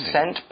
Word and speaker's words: scent: Okay, the word scent is spelled S-C-E-N-T. Watch out for that C because it's kind of a silent letter.